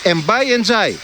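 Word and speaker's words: Ndiaye: Εντζάι, ναι. Τελικά έτσι προφέρεται το όνομά του, γιατί μας έχουν μπερδέψει και οι αντίστοιχοι ποδοσφαιριστές που έχουν το ίδιο επώνυμο.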